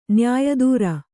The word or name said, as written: ನ್ಯಾಯದೂರ